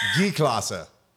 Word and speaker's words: G-Klasse: But like a G-Wagon, they call it a gay. Don't say gay. G-Klasse. – G-Klasse? – Yeah. – Like G-Class. – But they almost say it like gay. – Gay-Klasse.